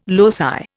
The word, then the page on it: loci: Indian